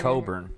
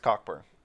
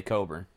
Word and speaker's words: Cockburn: It's Coburn, by the way. – Right, I think it's Cockburn. – That's definitely the way it's spelled, but it's definitely Coburn.